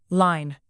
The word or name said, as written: line